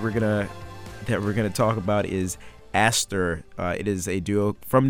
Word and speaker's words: ASTR: You can omit the O because they are strictly A-S-T-R, yet it's pronounced Aster.